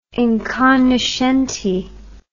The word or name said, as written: incognoscenti